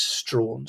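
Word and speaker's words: Strahan: For a long time, I didn't know how to pronounce his name, but I've been assured it is Strawn, so we'll go with that.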